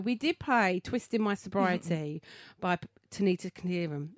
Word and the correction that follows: Tikaram: Have I said it right? – No. – No. – Tikaram.